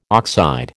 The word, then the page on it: oxide: American English